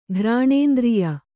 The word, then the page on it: ಘ್ರಾಣೇಂದ್ರಿಯ: ghrāṇēndriya